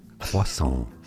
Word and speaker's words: croissant: In French, this short pastry is called croissant. Croissant in English.